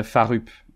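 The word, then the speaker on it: Fårup: Euh, sinon, ouais, Forup euh, au Danemark. Donc c'est pour les francophones, je dirais Farup. ça s'écrit Farup, on va dire.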